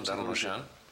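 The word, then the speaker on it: Darnauchans: Siempre que pienso en Eduardo Darnoyan, que así se pronuncia, ¿no? Darnoyan, por eso te dicen Darno.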